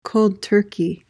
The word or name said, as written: cold turkey